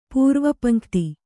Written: ಪೂರ್ವಪಂಕ್ತಿ

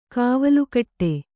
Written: ಕವಲ್ಗಿಡಿ

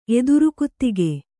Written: ಎದುರುಕುತ್ತಿಗೆ

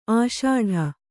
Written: ಅಷ್ಟು

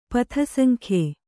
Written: ಪಥಸಂಖ್ಯೆ

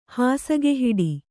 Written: ಹಾಸುಗೆಹಿಡಿ

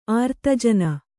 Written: ಆರ್ತಜನ

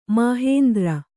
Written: ಮಾಹೇಂದ್ರ

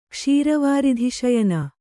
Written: ಕ್ಷೀರವಾರಿಧಿಶಯನ

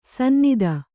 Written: ಸನ್ನತ

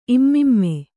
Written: ಇಮ್ಮಿಮ್ಮೆ

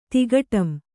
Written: ತಿಗಟಂ

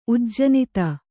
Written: ಉಜ್ಜನಿತ

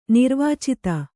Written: ನಿರ್ವಾಚಿತ

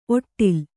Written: ಔಡಲ

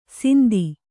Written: ಸಿಂದಿ

